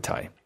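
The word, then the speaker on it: Ti: Here's my chat with Ty.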